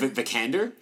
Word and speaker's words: Vikander: Vikander? – Yeah.